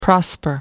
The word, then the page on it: prosper: American English